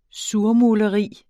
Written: surmuleri

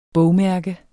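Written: bogmærke

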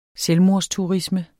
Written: selvmordsturisme

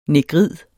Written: negrid

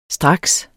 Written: straks

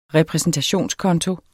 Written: repræsentationskonto

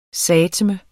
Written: sateme

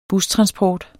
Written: bustransport

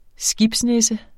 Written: skibsnisse

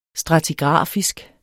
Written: stratigrafisk

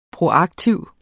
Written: proaktiv

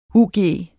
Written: ug

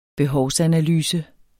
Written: behovsanalyse

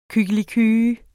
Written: kykliky

